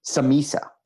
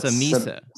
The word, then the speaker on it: Samesa: Is it pronounced Samesa? – Samisa. – Samisa. – But Samesa. Samesa is okay. – It's been mispronounced and butchered so many times, you're accepting alternate pronunciations. – I'm accepting alternate pronunciations. I don't love Samesa, so that I will correct people.